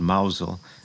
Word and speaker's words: Mousehole: village called Mousel. It's spelled Mousehole in Cornwall.